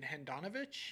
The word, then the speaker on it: Handanovic: Adanovic. You mean Handanovic? – Yeah, whatever you call him.